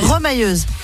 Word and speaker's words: remailleuse: Remailleuse. – Ça se prononce pas comme ça? Remailleuse? – Remailleuse, oui. – Ah, remailleuse, pardon.